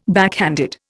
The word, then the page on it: backhanded: Indian